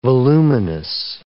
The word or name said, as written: voluminous